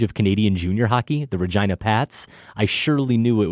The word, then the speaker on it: Regina: Regina, Saskatchewan. The name of that city isn't pronounced like the woman's name, Regina. The I is pronounced I, not E. A helpful pronunciation guide. It rhymes with vagina.